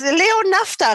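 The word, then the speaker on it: Naphta: Wird der so ausgesprochen? – Ja. ja. Der eifernde Jesuit aus dem Zauberberg. – Also, das leo nafta rennen Ihr müsst wieder Sieger finden.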